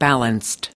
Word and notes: balanced: American English

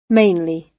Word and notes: mainly: British English

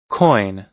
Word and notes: coin: American English

